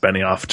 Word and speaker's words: Benioff: I believe that's pronounced Benioff, just for future reference.